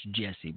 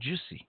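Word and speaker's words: Jussie: I know it's pronounced Jesse, but it's spelled Juicy.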